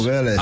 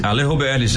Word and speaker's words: Vélez: Alejo Vélez. Se você for pronunciar Vélez, como lá na Argentina, é em portenho, né? Que é um pouco. – Alejo. – Seria Alejo Vélez. – Alejo Vélez, é verdade.